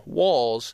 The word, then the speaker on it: Walls: And then you add to that the fact that my last name, Walls, rhymes with a moniker for testicles.